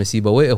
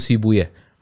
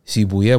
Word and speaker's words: سيبويه: وهذه نقطة مهمة احنا ننطق اسمه سيبوي هو المفروض يقول سيبويا سيبويا اي بالفارسية ماكو حتى ذاك اليوم طالع محاضرة حق دكتور حسين نصر يتكلم عن هذا الموضوع يقول هي, هي هو عامة ننطقها سيبوي لكن هو النطق الفارسي الصحيح سيبويا